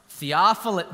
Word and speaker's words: Theophilus: Theophilus. Very good. You pronounced it well. I wanted to see who would trip over it. You did good.